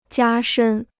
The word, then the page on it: 加深: jiā shēn